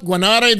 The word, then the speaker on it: Guanare: Guanare, is that where you grew up? Guanare, Venezuela? – Yeah, good.